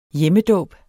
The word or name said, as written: hjemmedåb